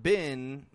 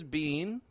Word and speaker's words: been: Now, this is not bin like B-I-N, but this is bin with the spelling B-E-E-N. Bin. I've already been there. I've been waiting. What have you been doing? Bin. You can pronounce this bean. You can say, I've been waiting. What have you been doing? People will understand you just fine if you say bean. But if you change that E sound to a quick I, bin, you'll have a little bit more control over your syllable stress and a better ability to link your words together.